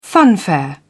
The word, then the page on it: funfair: British English